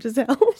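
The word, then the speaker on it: Gisele: What does Gazella call it? Gazella. Are they still together, Tom and Gazella? – You know that's not how you say her name, right? – Giselle.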